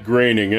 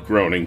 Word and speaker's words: Groening: Who the hell is Matt Groening anyway? – Isn't it Groening? – Well, it's spelled Groening, but I believe it's pronounced Groening.